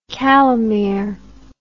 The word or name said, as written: calimere